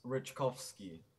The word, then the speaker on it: Ryczkowski: So is it Jan, first of all? – Yeah, it's Jan, and then it's Ryczkowski.